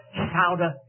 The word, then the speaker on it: Scioto: There goes through that area of the country what is called the Sciota Trail. It's spelled S-C-I-O-T-O, but they call it the Sciota Trail.